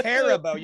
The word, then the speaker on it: Haribo: It's Haribo. You ever hit the pronunciation? – The Star Haribo. – Mix. – That's how the company says it, you stunod.